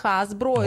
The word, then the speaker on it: Hasbro: Hasbro, да, правильно если так произносить? – Да, это Hasbro, это такой мармеладные мишки.